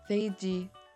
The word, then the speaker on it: 飞机: In Korean, it's easy to say because there's no F sound in Korean. But we can just go with the English pronunciation and say 飞机 with the F sound instead of a P sound.